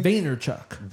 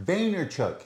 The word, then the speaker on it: Vaynerchuk: Bro, Gary Van, how do you say his last name? – Vaynerchuk. – Vaynerchuk.